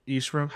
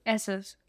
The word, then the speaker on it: Essos: And stuff, or Eastros. How do you say it? – Essos.